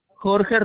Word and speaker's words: Jorge: Jorge Robles. – Spell that for me, please. – J-O-R-G-E.